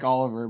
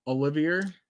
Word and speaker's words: Olivier: It's almost like Oliver, but it's Olivier.